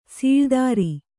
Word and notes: ಸೂಳುತ್ತರ: sūḷuttara

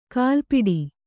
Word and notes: ಗಲಪಟ್ಟಿ: kannada; galapaṭṭi

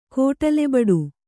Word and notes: ಕೋಟಲೆಬಡು: Kannada

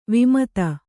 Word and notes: ವಿಮದ: vimada

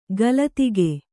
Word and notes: ಗಲತಿಗೆ: galatige

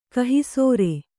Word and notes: ಕಹಿಸೋರೆ: kahisōre

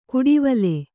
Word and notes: ಕುಡಿವಲೆ: kuḍivale

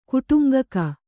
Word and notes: ಕುಟುಂಗಕ: kuṭuŋgaka

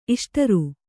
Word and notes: ಇಷ್ಟರು: Kannada

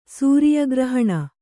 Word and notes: ಸೂರಿಯಗ್ರಹಣ: Kannada

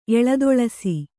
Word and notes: ಎಳದೊಳಸಿ: eḷadoḷasi

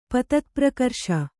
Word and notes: ಪತತ್ಪ್ರಕರ್ಷ: patatprakarṣa

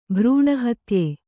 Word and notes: ಭ್ರೂಣಹತ್ಯೆ: kannada

